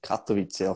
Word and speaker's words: Katowice: Ja, also genieße so Gratulation auf dem Weg auch noch einmal zum Titel in äh, Polen ähm, mit Katowice, hoffe ich, das richtig ausgesprochen. – Einigermaßen.